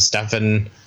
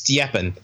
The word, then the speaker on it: Stjepan: You know, we have Steven, I guess he tells me to say it. We have Steven. – How do you say his name? – Well, apparently it's Stjepan because he's Croatian.